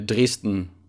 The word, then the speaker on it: Dresden: And In Saxony, Dresden. I think you pronounce it Dresden in English.